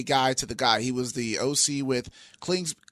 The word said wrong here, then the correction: Cliff Kingsbury: uh, Cliff Kingsbury. I can't say his name. – Cliff, Cliff Kingsbury.